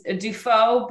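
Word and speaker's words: Dufault: It's my last name is Defoe, but it is D-U-F as in Frank, A-U-L-T as in Todd. – There so, you go. – Like default. So you can find me there on Instagram.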